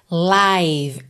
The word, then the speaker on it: live: Então vamos começar pela pronúncia. Live. Também se escreve L-I-V-E. O E final também não é pronunciado. Só que esse I aí é o I longo, tá? Com aquele som aberto, como se fosse AI, um ditongo.